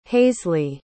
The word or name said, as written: haisley